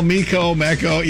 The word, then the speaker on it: Meco: Did I pronounce that right? – Well, Mecco. Yeah, M-E-C-O. There you go. You got it.